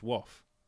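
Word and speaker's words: Waugh: We had an email from George, and I believe his surname is pronounced Wauch. It's W-A-U-G-H.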